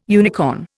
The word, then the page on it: unicorn: Indian